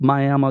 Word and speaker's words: Miami: And yes, it's pronounced Miami, it's spelled Miami.